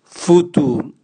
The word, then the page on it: futu: Raga